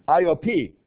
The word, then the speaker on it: Iope: And some brethren from Iope, is actually how it's pronounced, but from Joppa, accompanied him.